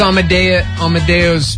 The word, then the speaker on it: Amadeus: Amadeus. I say Amadeus. Is it Amadeus? – Amadeus. – Really? Are you sure? – There's Amadeus Pizza here in town. That's Amadeus. Amadeus. – Amadeus. Yeah. I've been saying it wrong all my life.